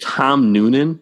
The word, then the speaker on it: Tom Noonan: Is it Tom Noonan? Do you think that's how you pronounce that? – Absolutely. Yep.